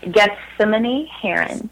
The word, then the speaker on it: Gethsemane Herron: Gethsemane Heron.